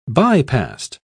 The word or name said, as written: bypast